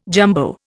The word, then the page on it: jumbo: Indian